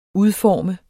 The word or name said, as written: udforme